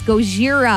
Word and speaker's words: Gojira: It's Gojira. I'll spell it for you. G-O-J-I-R-A.